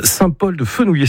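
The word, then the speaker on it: Saint-Paul-de-Fenouillet: Saint-Paul-de-Fenouillé, c'est comme ça que vous le prononcez. – Ouais, c'est ça.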